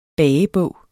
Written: bagebog

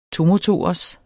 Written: tomotorers

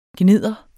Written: gnidder

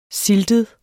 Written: siltet

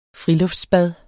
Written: friluftsbad

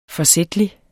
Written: forsætlig

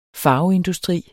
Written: farveindustri

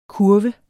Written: kurv

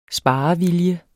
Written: sparevilje

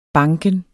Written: banken

